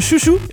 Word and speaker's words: Xiu Xiu: Ça se prononce comme ça. – X-I-U, X-I-U. – Oui, bon, ça, oui, ben oui, ben c'est sûr. – Chou-chou. – Hein? Ah, voilà. – Chou-chou. – Chou-chou.